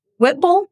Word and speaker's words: Whipple: So we're talking about Whipple.